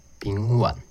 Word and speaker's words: 丙烷: 第一个是 CO2，二氧化碳；第二个是 NH3，氨，啊，就是阿莫尼亚，臭臭的那个东西；第三个是 Propane，丙烷，但我知道很多人会把它念成丙烷，啊。其实应该是二声啊，丙烷啊，但我听到不是念丙烷，就是念丙烷。